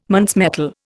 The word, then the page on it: Muntz metal: Indian